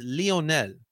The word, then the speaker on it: Lionel: It's Lionel. Lionel. All right. Not Lion. It's the amount of reporters I've heard say Lionel Messi when it's Lionel Messi will be coming to Chester, Pennsylvania, and facing off against our Philadelphia Union in a semifinal matchup.